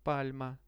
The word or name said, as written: palma